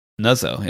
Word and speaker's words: Nuzzo: You know, how would you pronounce that? N-U-double-Z-O. – Nuzzo, yeah.